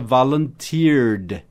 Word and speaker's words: volunteered: Ou oferecer, offer, O-F-F-E-R, offer. E no passado, é só você colocar o ED. Então, volunteer, no passado, fica volunteered. Não volunteered. Volunteered. Por que não pronuncia o E?